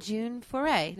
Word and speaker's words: June Foray: Yeah, and June Foray. Did I say that right? – Oh, yes, absolutely.